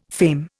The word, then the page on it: fame: Indian